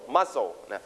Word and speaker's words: muscle: You stress on the first or second syllable First or second syllable? First, muscle.